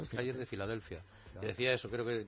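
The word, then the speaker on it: Philadelphia: Sí, Filadelfia, las de las dos F, dicen, y no tiene ninguna. Pero se pronuncia Filadelfia, PH.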